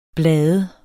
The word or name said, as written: blade